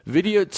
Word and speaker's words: Vidyut: Vidyut says people... – Is his name Vidyut? – Well, that's how I'm pronouncing it. – Yeah, I like it. It's kind of like video idiot.